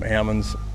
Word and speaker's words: almonds: We pronounce them almonds.